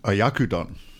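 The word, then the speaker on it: Oyakodon: Oyakudon.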